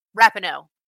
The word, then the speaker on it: Rapinoe: Rapinoe. – Okay. – Rapinoe.